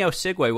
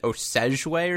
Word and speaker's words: Osigwe: So I worked on a long time on that pronunciation. I think I saw a video with the USTA where you said someone once pronounced it Osegue or something. – Yeah, I've gotten all kinds of things like Osage, Osigwe, like there's just so many different pronunciations that I get every single time, whether it's from refs, commentators, players.